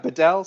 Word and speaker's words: Bedell: It's been and that's Bedell Winery, is that how you pronounce it? – Yeah, Bedell Cellars, B-E-D-E-L-L.